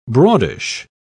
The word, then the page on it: broadish: British English